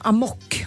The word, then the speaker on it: amok: Och uttalas tydligen amok, om det ska vara.